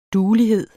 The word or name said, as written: duelighed